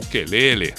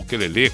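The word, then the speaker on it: ukulele: O Lulu Santos presenteando a audiência da Atlântida Floripa com o quelele ou o quelele, como queira. As duas pronúncias são aceitas, uma na sua forma original do Havaí, na outra mais abrasileirado. Não tem problema.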